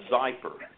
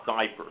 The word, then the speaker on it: Xypper: And it's called Zyper, and it's spelled XYPPER, Zyper.